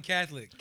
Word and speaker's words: Catholic: He pronounced the O in Catholic. – Catholic. – He like Catholic. – How do you guys pronounce it? Catholic. – Two syllables.